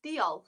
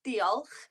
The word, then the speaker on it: diolch: So thank you in Welsh is diolch. So have a go saying that to yourself, diolch. And it's got that little bit of a different sound at the end, the ch, which is the CH.